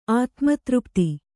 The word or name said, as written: ಆತ್ಮತೃಪ್ತಿ